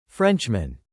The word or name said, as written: Frenchmen